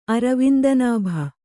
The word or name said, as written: ಅರವಿಂದನಾಭ